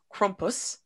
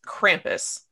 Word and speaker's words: Krampus: The proper pronunciation, as I have been taught today, is Crumpus, But here in the United States, we know it as Krampus.